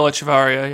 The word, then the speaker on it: Echeverry: But, you know, the long jump with Echeverry, is that how you say it? – Juan Miguel Echeverry, yeah.